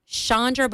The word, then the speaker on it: Chandra: Chandra, but it's spelled C-H-A-N-D-R-A. Chandra Levy.